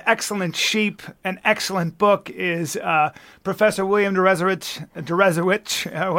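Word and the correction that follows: Deresiewicz: Derezowitz. – Rhymes with Jesuits.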